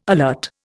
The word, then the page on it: alert: Indian